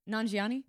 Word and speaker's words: Nanjiani: Is that how you say it? Nanjiani? – Nanjiani.